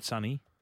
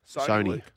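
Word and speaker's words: Soni: It's not Sonny. – Sony. – Sony? – Sony Luke. – As in like Sony the brand. – The brand, yeah. Yeah, I believe so.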